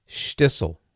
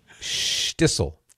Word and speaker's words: Shtisel: Stissel. – What? I'm going to need you to spell that for me. – Stissel. S-H-T-I-S-E-L. Stissel.